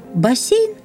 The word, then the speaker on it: бассейн: Как правильно, бассейн или бассейн? Открываем орфоэпический словарь «бассейн». И никаких пометок, что означает только одно – слово произносится в соответствии с написанием, то есть согласный звук перед «е» произносится мягко.